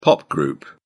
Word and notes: pop group: British English

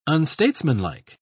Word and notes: unstatesmanlike: British English

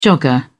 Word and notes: jogger: British English

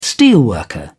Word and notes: steelworker: British English

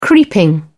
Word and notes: creeping: British English

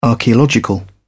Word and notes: archaeological: British English

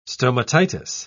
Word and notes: stomatitis: British English

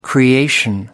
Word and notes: creation: American English

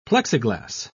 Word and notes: plexiglass: British English

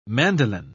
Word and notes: mandoline: British English

